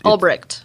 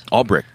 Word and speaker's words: Ulbricht: It's Ulbricht. – Ulbricht, that's it.